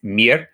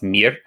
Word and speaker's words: mir: The word for good is mir. It's spelled like the English word Maya, but pronounced mir.